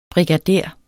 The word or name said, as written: brigader